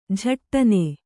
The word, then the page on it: ಝಟ್ಟನೆ: Kannada